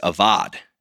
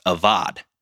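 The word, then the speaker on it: avad (עבד): And the original word used here is avad. It's spelled A-B-A-D. Okay, a Hebrew word pronounced avad.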